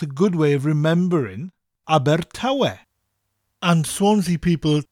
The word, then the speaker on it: Abertawe: Abertawe. Spelt A-B-E-R, Aber, with that rolling R sound, and Tawe, T-A-W-E.